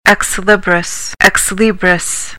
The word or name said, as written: ex libris